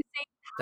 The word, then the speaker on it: Teigen: Model and Cravings author Teigen has been... – Why are you saying Teigen? – That's how you pronounce it.